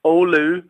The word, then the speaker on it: Oulu: Oulu, in northern Finland, about 220 kilometres just south of the Arctic Circle.